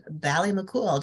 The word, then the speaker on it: Ballymacool: Ballymacool.